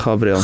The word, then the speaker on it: Gabriel: So we've got G-A-B-R-I-E-L, which one would say would be Gabriel. However, this is not going to be this easy, of course. It's the same name, right, but different pronunciation? Yeah? – Yeah.